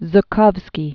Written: Louis Zukovsky

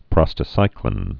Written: prostacyclin

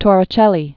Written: Torricelli

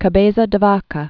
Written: Alvar Nunez Cabeza de Vaca

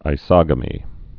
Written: isogamous

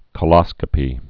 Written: coloscopy

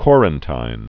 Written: Corantijn

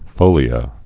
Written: folia

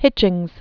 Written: Hitchings

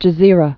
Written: El Gezira

